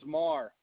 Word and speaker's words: Maher: My first name is Tim, T-I-M. – All right. And what's your last name? – The last name is Marr. It's spelled M-A-H-E-R.